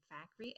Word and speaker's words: Fakhri: Yes, Zahra, Z-A-R-A, and Fakhri, F-A-K-H-R-I.